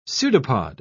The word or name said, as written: pseudopod